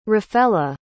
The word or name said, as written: rafela